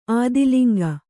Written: ಆದಿಲಿಂಗ

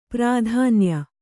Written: ಪ್ರಾಧಾನ್ಯ

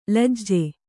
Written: ಲಜ್ಜೆ

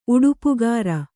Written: ಉಡುಪುಗಾರ

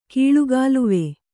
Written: ಕೀಳುಗಾಲುವೆ

